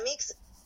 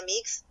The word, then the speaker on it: Elenamics: Eh, eh, mi nombre es Elena Ramírez pero muy probablemente todos ustedes me conocen como Elena Mix. Así lo pronuncio yo, pero hay gente que lo pronuncia como Elena Mix. Eh, realmente cualquier forma que lo digan está bien, no tengo problema.